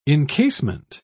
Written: encasement

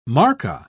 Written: markka

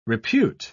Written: repute

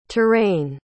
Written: touraine

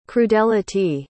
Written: crudelity

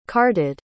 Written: carted

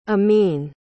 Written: amin

